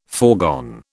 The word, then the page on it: foregone: British English